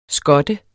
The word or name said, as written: skot